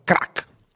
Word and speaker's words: Crack: È Giorgio Ghibaudo della redazione di una rivista nuova, nuovissima, che vede l'aria, vede la luce oggi e si chiama Rivista Crack. Ho detto bene? – Sì, esattamente.